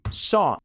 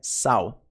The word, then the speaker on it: saw: Só é o passado do verbo see, ver. Então, ele viu. Ok? Pronúncia só. Só. Não é saw. É só.